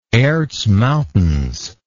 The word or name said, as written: Erz Mountains